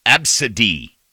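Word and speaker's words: ABCDE: Yes, the first five letters of the alphabet, just like that, pronounced Absidy.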